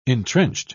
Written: entrenched

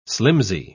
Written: slimsy

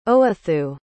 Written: owethu

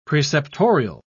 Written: preceptorial

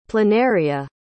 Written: planaria

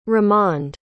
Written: ramond

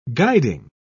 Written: guiding

